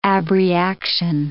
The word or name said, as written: abreaction